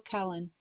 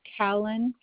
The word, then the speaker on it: Khallen: Jewel Cullen. – Spell that for me, please. – The first name and the last name, or? – Yes. – J-U-H-L for the first name. Callen for the last name, which is K-H-A-L-L-E-N.